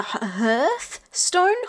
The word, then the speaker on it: Hearthstone: Okay, do you know how you say the word heart? – The hearthstone? – Yeah, just like pronunciation level. Pronunciation lesson. You know, how say the word heart. – Heart? No, heart. – Okay. Now say it with a th sound at the end.